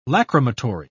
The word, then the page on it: lacrimatory: British English